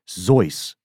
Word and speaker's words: Seuss: And I think you and I probably had a similar moment later in life where we realized that his family originally pronounced the name Seuss as Zeus. Like Z-O-I-C-E.